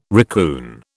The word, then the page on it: raccoon: British English